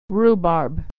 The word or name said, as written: rhubarb